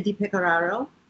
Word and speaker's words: DiPecoraro: And Sravaniya DiPecoraro, and I'm an initiated disciple of His Divine Grace A.C.